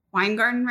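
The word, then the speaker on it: Weingarten: And just so you know, Wine Garden Rights, in case you want to do some research, is spelled WEINGARDEN.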